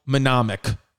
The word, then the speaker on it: mnemonic: If you ever are lost in the woods, little survival tip, remember the classic survival mnemonic. How do you say that word? M-N-E-M-O-N-I-C. Is it mnemonic? Mnemonic. Mnemonic.